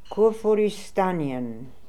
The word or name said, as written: Koorfooristanian